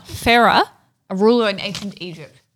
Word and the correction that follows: Pharaoh: Oh, no. I didn't say that right. – Pharaoh? – Is it Pharaoh? – Pharaoh. P-H. – That's it. Pharaoh. Yes. – A-R. – Yes. – O-A-H.